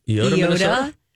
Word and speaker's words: Eyota: How do you say E-Y-O-T-A? – E-Y-O-T-A. – Eota? – Eota, yeah.